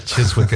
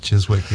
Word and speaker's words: Chiswick: Chiswick is not Chiswick, yes. Like so many English place names.